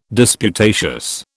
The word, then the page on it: disputatious: British English